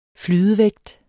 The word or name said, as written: flydevægt